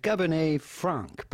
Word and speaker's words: Cabernet Franc: Cabernet Franc, but the T at the end of Cabernet is still silent, Cabernet Franc. But for the typical French pronunciation, you do not want to pronounce the C at the end of Franc because it is silent in French, as many letters, last letters of words are just like the T of Cabernet.